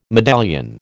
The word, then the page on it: medallion: American English